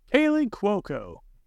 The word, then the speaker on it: Kaley Cuoco: Kaley Cuoco.